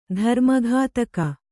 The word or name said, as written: ಧರ್ಮಘಾತಕ